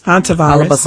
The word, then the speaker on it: Hantavirus: Hantavirus. – Hantavirus.